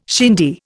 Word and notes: shindy: Indian

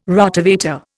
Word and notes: Rotovator: Indian

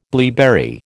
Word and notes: blaeberry: American English

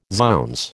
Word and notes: zounds: American English

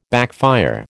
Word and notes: backfire: American English

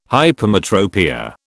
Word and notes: hypermetropia: British English

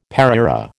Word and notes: pareira: American English

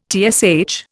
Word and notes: TSH: Indian

